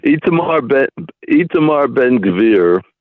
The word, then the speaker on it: Itamar Ben-Gvir: And I'm talking about Itamar Ben-Gvir, if I'm pronouncing it correctly. Who is he? – You are. – And why is everybody so afraid of him? – Well, I'm actually very impressed that you pronounced it correctly.